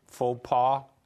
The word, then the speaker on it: faux pas: You can't really know truth, and if you claim you do, that's a huge faux pas.